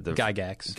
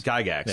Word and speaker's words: Gygax: how do you pronounce the Gygax. – Gygax, yeah.